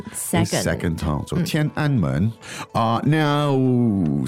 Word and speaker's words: 天安门: 天安门. 天安门. – Great. 天安门. Now, as we all know in English, it's 天安门. So the pronunciation with the tones here are quite important.